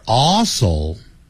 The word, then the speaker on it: also: We are also, also, 你看 ,AL 有一个 L, 所以 all, 舌尖抵上去 ,also, 你不能懒惰 ,also, 如果你念成 also, also, 这舌尖没有抵上去就不对 ,also, we are also good friends.